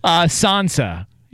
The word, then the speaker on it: Sansa: How do you say this? – Sansa. – Yeah, Sansa. Yeah.